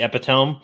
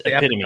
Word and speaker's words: epitome: That's or as I've heard many people mispronounce it, the epitome. I'm like, no, it's epitome, guys.